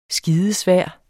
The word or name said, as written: skidesvær